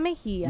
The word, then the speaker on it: Mejia: My name is Julia Mejia.